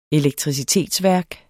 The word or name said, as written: elektricitetsværk